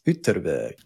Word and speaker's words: Ytterby: So I asked a guy and it's Ytterby. Ytterby. – I'm not going to try that. – The Y is this weird Germanic Y sound that we don't use in English, but most of the other Germanic languages do.